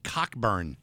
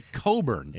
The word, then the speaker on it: Cockburn: Was it C-O-L-B-U-R-N? – No, it was, if you read it out, it said Cockburn. But he pronounced it Coburn.